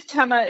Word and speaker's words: Tema: It is Tema. It's just Robbie.